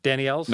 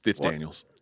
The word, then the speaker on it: Daniels: Danny Ells? No, it's Daniels.